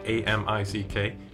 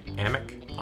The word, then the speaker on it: Amick: We've got random weight fresh chicken breast from Amick. I think I'm saying that right. A M I C K. – Amick.